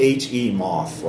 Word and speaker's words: Hemoth: It's spelled H-E moth, like he moth. – Yeah, but I was picturing Hemoth. – He moths, not like a behemoth.